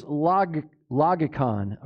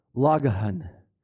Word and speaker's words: logikon: So this word spiritual service, the word is logikon, and actually, if you pronounce it correctly, it's logahan. The K is an H sound.